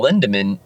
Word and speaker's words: Lindemann: And then switching the flip side to that is playing with Till Lindemann, you know, what makes it hard of Rammstein. Am I saying that right? – Yeah. And what makes it challenging with him is everything is in drop A.